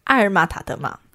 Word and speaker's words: 阿爾瑪-塔德瑪: 今天呢，要带大家看的作品哦，正是最顶级的埃及风情代表作，它是来自阿尔玛塔德玛的作品《发现摩西》。 – 这个画家的名字怎么这么不好念？ – 那你再念三次。 – 阿尔玛塔德玛，阿尔玛塔德玛，阿尔玛塔德玛，很好哦，发音正确。